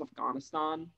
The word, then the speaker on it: Afghanistan: Speaking of most of the world's conflict, Afghanistan, or apparently it's pronounced Afghanistan. So I feel like I want to get better at that, but we'll see.